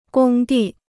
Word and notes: 工地: gōng dì

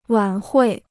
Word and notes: 晚会: wǎn huì

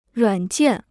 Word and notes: 软件: ruǎn jiàn

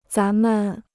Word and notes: 咱们: zán men

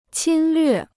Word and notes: 侵略: qīn llüè

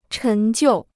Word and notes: 陈旧: chén jiù

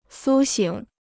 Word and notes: 苏醒: sū xǐng

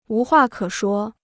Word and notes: 无话可说: wú huà kě shuō

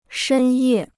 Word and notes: 深夜: shēn yè

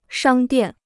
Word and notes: 商店: shāng diàn

